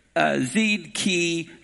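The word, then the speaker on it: Zedekiyahu (צדקיהו): And here it's Zedekiyahu. That would be the Hebrew or pronunciation, but we just say Zedekiah in English.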